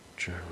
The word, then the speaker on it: Jeroo: J-E-R-O-O. – Giroux.